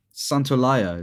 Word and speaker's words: Santaolalla: Santaolalla is how you say his name. Gustavo Santaolalla. Yeah.